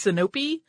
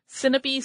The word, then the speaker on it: Sinope: Some, put an accent on the middle syllable. So it's Sinope. Sinope sounds more natural to me. So we're going with that.